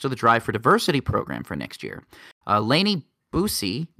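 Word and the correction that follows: Buice: Bice. – That's how you spell it? Okay, I'm so sorry. Yeah. That is under no way, shape, or form how I thought that was going to be pronounced.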